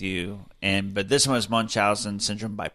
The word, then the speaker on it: Munchausen: Munchausen.